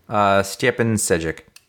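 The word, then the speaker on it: Stjepan Šejić: How do you pronounce that name? – Stjepan Sejic. – That sounds right.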